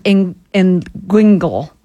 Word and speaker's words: inguinal: So we hear the term inguinal. And am I saying that correctly? Inguinal? – Yeah, it's hard to pronounce.